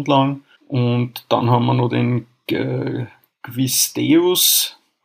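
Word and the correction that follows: Quis ut Deus: habe ich das richtig ausgesprochen? – In Quiz ut Deus, der Quiz der Barde.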